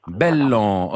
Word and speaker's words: Bélan: On se met d'accord sur l'orthographe du nom, B-E-2-L-A-N, c'est bien ça? – Voilà, qu'on prononce Bellan.